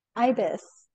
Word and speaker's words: Eibes: Is it Eibes? Did I say it right? – Ibis. It's a Eibes.